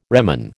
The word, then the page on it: reman: American English